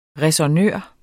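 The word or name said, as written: ræsonnør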